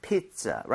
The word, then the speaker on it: pizza: 피자, right? I에 강조해주면서 마치 P-I-T-Z-A인 것처럼 발음하시면 네. – 훨씬 더 자연스럽게 나오죠.